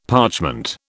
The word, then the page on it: parchment: British English